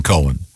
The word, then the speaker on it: Cohen: Leonard Cohen. – Cohen. Yeah, you. – This that's confusing because no, not when it's spelled C-O-H-E-N. – Yeah, it's definitely Cohen.